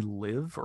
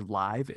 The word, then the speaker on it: Liv: Sony Live or Live. It's spelled L-I-V.